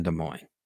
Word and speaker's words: Des Moines: I don't think the S is pronounced in Des Moines.